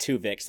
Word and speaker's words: Twovix: And on YouTube, Opinions No One Cares About said, talking about the season premiere episode, Tuvix, that's spelled T-W-O-V-I-X.